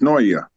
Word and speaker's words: Senoia: Um, little town that I'm from, the locals we call it Snore, Georgia, but as you look at the spelling of it, it is uh, pronounced Senoia, Georgia.